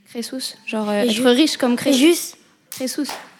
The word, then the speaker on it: Crésus: Je connais que Crésus.